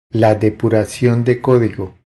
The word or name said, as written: La depuracion de codigo